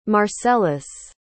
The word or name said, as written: marcellus